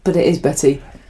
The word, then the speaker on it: Bette: Betty. It's spelt Bet. – Yeah. – With an E. But it's Betty.